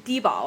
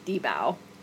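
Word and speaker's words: 低保: So in Chinese, it's Dibao, or 最低生活保障. So it's short. It's an acronym kind of for this minimum livelihood guarantee. But I think the anglicized pronunciation would just be Dibao.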